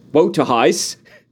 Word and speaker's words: Waterhouse: the British pronunciation of Lawrence Waterhouse's surname is written out as W-O-E space T-O space H-I-C-E, Wotahice.